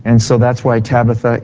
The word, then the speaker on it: Tabitha: Now, Tabitha is the Syro-Chaldaic way to say her name.